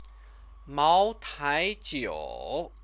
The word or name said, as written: maotaijiu